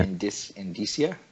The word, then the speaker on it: indicia: How do you pronounce it? Is that indica? – No. Indicia.